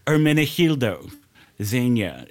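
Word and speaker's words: Ermenegildo Zegna: Hermenegildo Zegna. It was the Italian designer that made William LaPierre suits. – Oh, you got it. You nailed it. That's it. That's the one.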